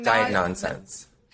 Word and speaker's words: Diagnonsense: diagnosense. Am I saying that right? Diagnos- Diagnonsense. – Diagnonsense. – Just so like nonsense.